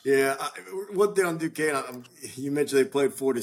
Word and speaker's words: Duquesne: Now they, I had to correct them because I, you know, went to school by Duquesne. I said, it's Duquesne. So now they got kind of got their brand name and everyone in Florida knows who they are now.